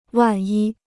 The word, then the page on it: 万一: wàn yī